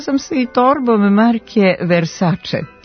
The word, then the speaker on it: Versace: Iako se prezime poznate italijanske porodice Versace čita sa ć, ovakav izgovor nema uporište u pravopisu. Prema pravilima transkripcije, italijansko c se ispred vokala e i i čita kao č, Versace.